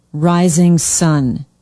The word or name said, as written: rising sun